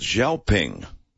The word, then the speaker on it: XPeng: I'm told it's pronounced Xiaoping.